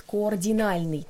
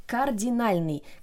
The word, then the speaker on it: кардинальный: очень часто слышно, как произносят координальный. Например, это были координальные перемены и так далее. Но на самом деле кардинальный. Кардинальный связано со словом кардинал, то есть это слово главный по-другому а, переводится как.